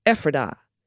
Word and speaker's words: Ephrata: in regards to the Central PA town names, Ephrata is pronounced Ephrata.